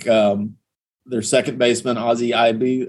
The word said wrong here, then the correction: Albies: I'm not going to pronounce his name right. Albies, sorry.